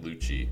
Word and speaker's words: Lucci: I believe that's how you pronounce it, Lucci. – Yeah. – L-U-C-C-I.